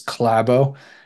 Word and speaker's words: Klæbo: So our boy Johannes Hustvedt Klæbo, I'm going to try to say this better, mostly because Leif has been commenting on it. To be slightly more Norwegian with my pronunciation, it is Klæbo.